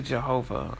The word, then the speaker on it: Jehovah: You may or may not be surprised, but that translates to Jehovah. I hope, and like I said, with pronunciation, forgive me. It looks like in English Y-E-H-O-V-A-H, but it's pronounced Jehovah as you see it in like Jehovah's Witness, for instance.